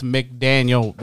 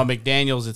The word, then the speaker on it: McDaniel: No, it's McDaniel without the S. – Okay. – There's no McDaniels, it's McDaniel.